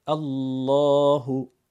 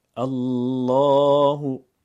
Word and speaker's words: الله: در قرآن کریم رسم الخط مصری شما میبینید الله، کلمه جلاله الله که نام مبارک خدای متعال هست، الف کوچک درش نوشته نشده. الله، ولی باید حتما اون الف ادا بشه. الله، الله.